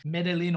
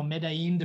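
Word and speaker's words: Medellín: Medellin or Medellin, depending on how you pronounce it, if you're Latino or not.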